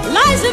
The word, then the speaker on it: Liza: It's Liza with a Z, not Lisa with an S, cause Lisa with an S goes snuzz. It's Z instead of S, lie instead of Lee. It's simple as can be.